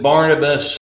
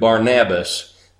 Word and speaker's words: Barnabas: We pronounce it Barnabas. For them, it was probably closer to Barnabas, because Nabas means encouragement, Bar means son of.